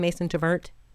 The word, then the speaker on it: Mason Tvert: He's the co-author with Mason Tvert. Is that how you pronounce his name, Mason Tvert? – It is.